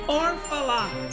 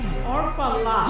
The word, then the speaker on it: Orfalea: Orfala is the correct way to say O R F A L E A. Reading it is kind of a pain. Pronunciation is insanity. There's no one to blame but my family.